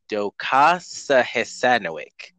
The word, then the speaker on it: docosahexaenoic: Their success is due to the fact that they seem to contain a high concentration of docosahexaenic acid, DHA. I'm not pronouncing that right. It's docosahexaenoic. D-O-C-O-S-A-H-E-X-A-E-N-O-I-C.